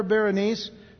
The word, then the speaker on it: Berenice: Ptolemy the Second Philadelphus, who was the king of Egypt from 285 to 246 BC, he would give his daughter Berenice, B-E-R-E-N-I-C-E, like Berry Nice, but Berenice is the way it's pronounced.